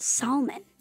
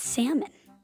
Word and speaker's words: salmon: It would be pronounced salmon, but it's salmon.